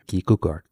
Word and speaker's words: Kierkegaard: And the great Danish philosopher, I hope I'm saying this right, Søren Kierkegaard. – Am I saying Søren Kierkegaard. – Yeah, Kierkegaard.